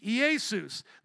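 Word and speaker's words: Iesus (Ἰησοῦς): As it's pronounced in the Greek, Iesus, they'll say Zeus.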